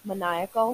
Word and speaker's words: maniacal: A maniacal, manic, maniacal, maniacal. Thank you. I got you, girl.